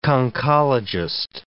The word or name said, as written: conchologist